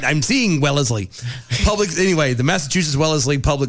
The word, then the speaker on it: Wellesley: Because my brain was reading it as Wesley. – Yeah. – And it is as Wellesley.